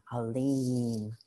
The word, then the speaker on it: Alim: It's A-L-I-M, pronounced A-L-E-E-M, Alim.